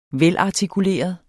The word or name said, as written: velartikuleret